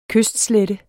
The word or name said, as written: kystslette